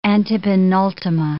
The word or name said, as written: antepenultima